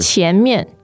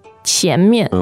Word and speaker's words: 前面: And then 前面, and that's second and fourth. Am I right? 前面. – Yes, the dictionary says it should be second and neutral. – Okay. – 前面. 前面. – Okay. – So you don't pronounce the second one as obviously. – Okay, but normally 面 would be the fourth tone.